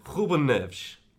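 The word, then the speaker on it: Rúben Neves: So you come under some stick because of the pronunciation of Ruben Neves, which is pronounced, apparently... – If you're a native speaker, you would say Ruben Neves.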